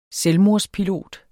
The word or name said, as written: selvmordspilot